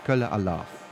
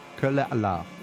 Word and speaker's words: Kölle Alaaf: In Cologne, you say Kölle Alaaf.